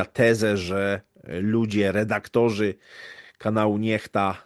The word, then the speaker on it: NEXTA: Ewidentnie tutaj mamy do czynienia z typowymi wrzutkami medialnymi, chociażby kwestia, no można powiedzieć, takiego czarnego PR-u dla kanału Niechta, czy Nexta, jak kto woli, ale tak jak twórcy sobie życzą, czyta się Niechta.